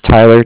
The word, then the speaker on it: Tyler: My name is Tyler, T-Y-L-E-R, and I'm with Cardiff. That's spelled C-A-R-D-I-F-F, as in Frank.